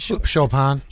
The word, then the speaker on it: Chopin: Is it Chopin? How do you say it? – Chopin.